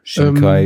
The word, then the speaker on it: Shinkai: Shinkai, ähm, Shinkai.